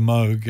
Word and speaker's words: Moog: Yeah, but the Moog didn't really, or the Moog as it's correctly pronounced, it didn't really hit the world until the Mini Moog came along, and that was championed by people like Kraftwerk.